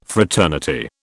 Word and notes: fraternity: British English

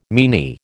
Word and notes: meany: American English

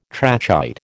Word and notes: trachyte: American English

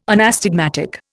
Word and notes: anastigmatic: Indian

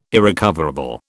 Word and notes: irrecoverable: British English

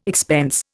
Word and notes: expense: Indian

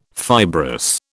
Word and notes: fibrous: British English

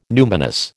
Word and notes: numinous: American English

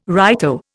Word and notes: righto: Indian

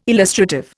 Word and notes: illustrative: Indian

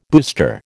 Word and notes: booster: American English

